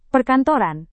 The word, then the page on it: perkantoran: Indonesian